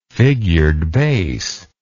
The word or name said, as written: figured bass